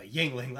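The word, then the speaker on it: Yuengling: Yep, a Yingling.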